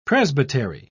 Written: presbytery

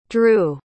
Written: drue

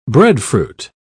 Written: breadfruit

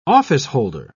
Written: officeholder

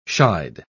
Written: shied

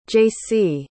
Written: jacee

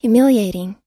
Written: humiliating

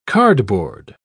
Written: cardboard

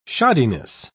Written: shoddiness

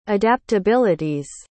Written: adaptabilities

